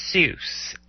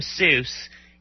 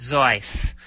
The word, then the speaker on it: Seuss: One of the things that people don't know about Dr. Seuss is that it's actually not pronounced Seuss. And one of his collaborators in Dartmouth wrote a little poem. You're wrong as the deuce, and you shouldn't rejoice. If you're calling him Seuss, he pronounces it Zeus. So, but he anglicized the pronunciation because he felt that it evoked an advantageous for an author of children's books to be associated with Mother Goose.